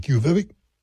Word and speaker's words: Quviviq: Quviviq? And if you look at how it's spelled, you never think that's how it's pronounced. Q-U-V-I-V-Q-U-U-U.